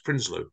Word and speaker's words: Prinsloo: Gavin Prinsloo, is that right? Prinsloo. – Perfect. Absolutely perfect pronunciation.